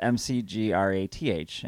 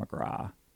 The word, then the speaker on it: McGrath: So our name is McGrath. In Ireland, that's pronounced McGrath. But most people say McGrath.